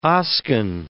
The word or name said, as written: Oscan